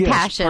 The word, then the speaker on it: Paschen: We should mention that the name of the challenger is Cindy Passion. – Yes, pardon me. I meant to say that. – Not spelled like Passion, but... – Yeah, P-A-S-C-H-E-N.